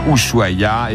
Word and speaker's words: Ushuaïa: Rappelle, hein, Olivier, Ushuaïa, et c'est bien prononcé, ce qui est assez rare, je dois dire.